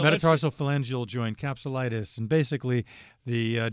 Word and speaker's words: metatarsophalangeal: Metatarsophalangeal. – You said it absolutely perfectly.